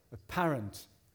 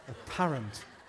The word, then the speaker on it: apparent: where maybe some of those gifts are more apparent. Why do you put the accent on the wrong place all the time? Apparent. Apparent.